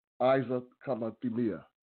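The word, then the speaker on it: Isicathamiya: I'm going to spell it for you as well, but I think it's pronounced Isaac Kamathia, Kamamia. Isaac Kamathimia. I-S-I-C-A-T-H-A-M-I-Y-A. I-S-I-C-A T-H-A-M-I-Y-A.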